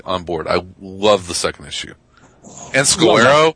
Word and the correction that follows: Scalera: is a fucking beast, man. – Scalera. – Is good too. – Scalera. Yeah. – Scalera. Oh, I've been mispronouncing names all night, so.